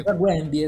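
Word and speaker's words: Zagłębie: Inizialmente pensavo fosse quella la squadra di Lublin, non so quale sia la pronuncia, Zaguembie. – Zaguembie, eh, Zaguembie, sì, sì.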